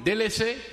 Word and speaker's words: délaissé: And we're going to do one délaissé. Am I saying that right? Délaissé? – Yeah, Owen Bruce.